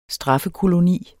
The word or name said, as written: straffekoloni